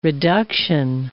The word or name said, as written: reduction